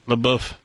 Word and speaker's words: LaBeouf: LaBeouf.